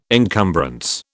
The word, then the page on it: encumbrance: British English